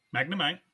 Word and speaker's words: Magnemite: Magmite. I thought there was an Magnemite. N in there. Because I just see Magmite. – Magnemite. – Okay, so it was spelled wrong.